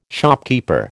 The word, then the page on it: shopkeeper: American English